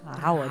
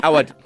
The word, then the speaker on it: Awad: Awad. – Awad. – Awad. – Yeah, Awad. – Okay. With so you've got letters A. – that Awad. I don't have. – You said it right. Awad.